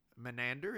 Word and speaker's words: Menander: and it's said to be a quote from Menander.